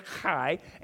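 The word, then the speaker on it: chai (חי): I'm telling you, this year, 2018, is the year of C-H-I-E, which is pronounced chai, and it's going to be life, and it's going to be life abundant in every avenue of our lives.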